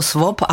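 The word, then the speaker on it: swap: svop, ono se to tak říká. – Já jsem se napřed toho lek, já jsem myslel, že to je nějaký vojenství. – To je anglicky S-2-T-V-A-P, čte se to svop a mnoho lidí si pod tím moc neumí představit.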